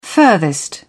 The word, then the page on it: furthest: British English